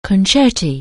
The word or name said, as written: concerto